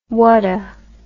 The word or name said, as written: whatta